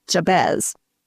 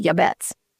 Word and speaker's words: Jabez: It's a short prayer, and it was prayed by a man who, in English, we pronounce it Jabez. But when I looked it up in the Hebrew, it's actually pronounced Yabetz.